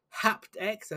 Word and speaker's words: HaptX: We've got a HaptX. Have I pronounced that right? – That's what I would have gone with.